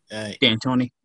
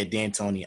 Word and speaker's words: D'Antoni: D'Antoni. – Yeah, D'Antoni.